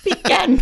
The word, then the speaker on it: pecan: It's got pecan pie. – You didn't say it right. Pecan pie.